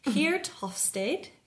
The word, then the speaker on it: Geert Hofstede: And I think it's about, hopefully I pronounce this correct as a Dutch person, Geert Hofstede, who is a Dutch person.